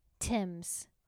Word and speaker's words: Thames: How do you pronounce it? – Thames.